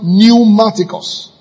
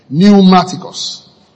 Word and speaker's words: pneumatikos: Now, the word spiritual there, pay good attention here, is spelled pneumaticos. P-N-E-U-M-A-T-I-K-O-S. Pneumaticos. P-N-E-U-M-A-T-I-K-O-S.